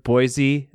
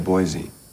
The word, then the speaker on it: Boise: to say something when they say the word Boise between Michael Fassbender and Penelope Cruz like 20 times in 2 minutes of screen time?